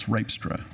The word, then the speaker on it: Rijpstra: It's Rijpstra. It's a Dutch name."